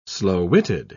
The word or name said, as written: slow-witted